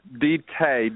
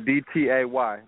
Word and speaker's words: D-Tay: Is it D-Tac? – D-Tay. D-T-A-Y. D-Tay the Hustler.